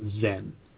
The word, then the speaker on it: Zen: And in Japan, that same word, Chan, is pronounced Zen.